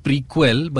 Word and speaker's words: Prequelle: The new album is called Prequel, but it's spelled as Prequelle.